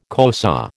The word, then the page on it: Xhosa: American English